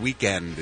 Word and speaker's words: Weeknd: Weeknd is how it's pronounced.